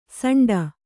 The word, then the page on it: ಸಂಡ: saṇḍa